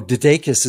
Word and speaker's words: Dedekis: Although Dedekis is one of those tricky names. It's like DeWattis.